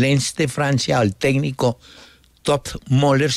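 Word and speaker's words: Toppmöller: Que me dicen que se pronuncia como, se escribe Topmöller, pero se pronuncia Topmöller.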